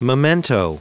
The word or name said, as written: memento